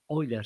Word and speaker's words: Euler: Un autorizado físico y matemático suizo llamado Leonard Euler, Euler se pronuncia en alemán,